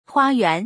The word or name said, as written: hua1yuan2